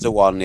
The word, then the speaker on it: DeJuan: There was a moment actually in the 49ers game where DeJuan Harris, is that how you call it? DeJuan Harris? – It's just DeJuan, yeah. – DeJuan Harris. – He's not Portuguese, it's a soft J.